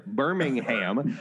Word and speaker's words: Birmingham: So I was just down in Alabama with my girlfriend, and we went to see the, I keep calling it Birmingham because my friends are from England and that's how you pronounce it there. Birmingham.